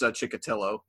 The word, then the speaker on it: Chikatilo: No, I was going to say I could do the pronunciation for these. Vasily Kulik, Andrea Chikatilo, Sergei Golovkin, Vasily Komarov. It's Chikatilo. It's the second one.